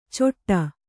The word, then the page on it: ಚೊಟ್ಟ: coṭṭa